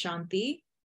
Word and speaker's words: shanti: I've learned that that word is pronounced shanti, and I will do my best to make sure that I always pronounce that word correctly, because it's sacred and it's a way to respect the culture.